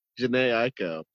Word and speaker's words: Jhené Aiko: I hate how you say that. – How do you pronounce it? I'm sorry. – That's everything. Jhené Aiko. You said, is that the Jhené Aiko feature? I was like, damn.